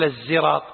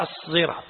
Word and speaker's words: الصراط: قال الصحيح أنه ليس بالزاي، وإنما بالصاد المشبعة الموصلة لمخرج الزاي. يعني ما يصير تقول الزراط، لا، هي الصراط هكذا.